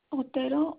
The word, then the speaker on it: Otero: Yes, Yadira, it's Y-A-D-I-R-A. – You said Y-A-D? – Otero. Uh-huh. I-R-A. – I-R-A. And spell that last name for me. – Otero, O-T-E-R-O.